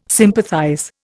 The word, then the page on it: sympathize: Indian